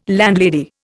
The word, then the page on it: landlady: Indian